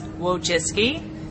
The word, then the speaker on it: Wojcicki: But it's Wojcicki, and it's Polish, and that's my name. It's actually not that hard to say. It just looks hard when you see the spelling.